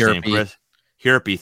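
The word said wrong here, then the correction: Hjerpe: thank you. Jerpy, there you go. – Hirpy's always no age. – Yeah, no H's. Drop the H, Jerpy. Like, HJ should never be put back-to-back. That's just a weird combination of letters.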